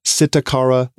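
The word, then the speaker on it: Psittacara: It's been assigned the scientific name of Sitacara brevipes. By the way, the root word there, sitta, P-S-I-T-T-A, comes from the Latin word for parrot. So there's a silent P, just FYI.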